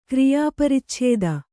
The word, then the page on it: ಕ್ರಿಯಾಪರಿಚ್ಛೇದ: kriyā paricchēda